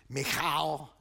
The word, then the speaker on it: Michal: You know, if I was saying it in Hebrew, it's Michal.